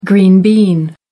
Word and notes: green bean: American English

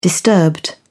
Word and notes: disturbed: British English

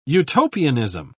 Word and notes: utopianism: British English

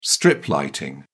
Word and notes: strip lighting: British English

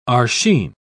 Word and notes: arshin: British English

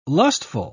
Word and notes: lustful: British English

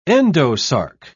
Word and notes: endosarc: British English

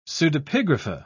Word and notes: pseudepigrapha: British English

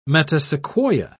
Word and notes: metasequoia: British English

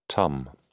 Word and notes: tum: British English

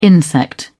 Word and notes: insect: British English

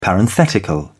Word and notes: parenthetical: British English

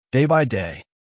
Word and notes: day by day: American English

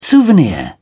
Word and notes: souvenir: British English

